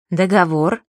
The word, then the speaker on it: договор: К ним относятся, например, такие слова. Благо. Благом, благами, о благах. Петля. Петлю, петлей, о петле. Договор. Договора, о договоре.